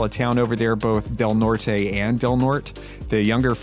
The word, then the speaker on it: Del Norte: Del Norte? It's spelled D-E-L-N-O-R-T-E.